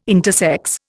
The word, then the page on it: intersex: Indian